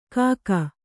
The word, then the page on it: ಕಾಕ: kannada